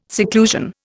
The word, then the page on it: seclusion: Indian